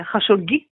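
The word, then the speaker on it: Хашогги: Хашоги. Вы знаете, что его имя можно по-разному произносить, но мы произносим, произносим его сегодня именно так.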